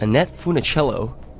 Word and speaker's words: Annette Funicello: Annette Funicello? Did I even say that right? – Yes. Yeah. Your voice is supposed to crack, but you did a nice job.